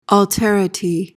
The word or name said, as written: alterity